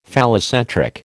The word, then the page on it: phallocentric: American English